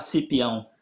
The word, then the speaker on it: Cipião: Romario Pereira Cipiao. Hej, god morgon. God morgon. Uttalade jag ditt eh, efternamn rätt där? Ja, Romario Pereira Cipião.